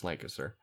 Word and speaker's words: Lancaster: And yes, that's how it's pronounced, Lancaster.